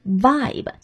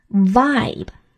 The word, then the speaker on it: vibe: 这里呢要注意 vibe 这个单词的读音，单词是以字母 v 开头，所以啊要注意用上牙齿轻轻咬住你的下嘴唇。 vibe vibe。